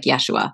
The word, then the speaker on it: Yeshua: Although it would have been pronounced more like Yeshua. So if we said Joshua, he might not respond because they wouldn't have said it that way. They would have said Yeshua.